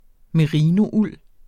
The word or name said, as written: merinould